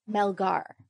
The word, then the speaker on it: Melgar: Melgar.